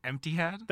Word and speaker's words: Etihad: You want to repronounce that one again? – The Etihad? – Thank you. Yeah, thank you.